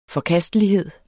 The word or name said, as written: forkastelighed